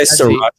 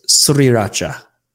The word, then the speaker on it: sriracha: Or people say sriracha all the time, but it's sriracha. Sriracha. – It is? – There's an R. S-R. Sriracha.